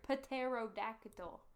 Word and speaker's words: pterodactyl: Pterodactyl. – I don't know. – Also, who said that the P is silent in that?